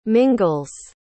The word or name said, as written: mingles